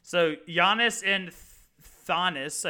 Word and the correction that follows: Thanasis: I think is how you pronounce his name. – I thought it was Thanasis. Maybe, I don't know. – Giannis and Thanasis.